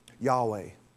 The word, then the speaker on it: Yahweh: It could be pronounced Jehovah and the same word, based on the way the um, vowel markings are placed, could also be um, Yahweh.